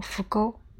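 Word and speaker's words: ふぐ: 日本人的河豚读成福沟，和神奇的福字同音，的确能享受此种美味，是口福。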